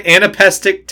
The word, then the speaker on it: anapestic: I wasn't asking about tetrameter. I was asking about anapestic. – Yeah, it is definitely anapestic. Okay.